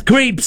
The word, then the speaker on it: Creep: So many people pronounce it as Crip. I giggle every time I hear it. That's Creep 749.